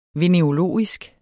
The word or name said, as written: venerologisk